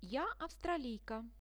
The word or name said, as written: ja avstraliika